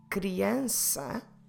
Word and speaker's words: criança: For instance, the word for child, criança, criança, it starts with a CR and we do the cru. And then in the middle we have the sound S, and there we will apply the cedilha.